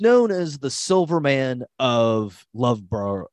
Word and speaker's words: Loughborough: Ricky Carter is from Loughborough, I think is how his relative said it was to be pronounced.